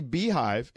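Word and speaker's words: Beyhive: Oh, by the way, Dre, young lady, tweets us, it is definitely Beehive. It's spelled Bay because her name is Beyonce. So, B. – Okay, well, everyone, I'm just saying, everyone always says Bay. – I always Bayhive.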